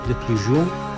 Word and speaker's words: Desplechin: De Arnaud Desplechin, no sé cómo se pronuncia. – Desplechin.